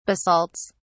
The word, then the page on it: basalts: British English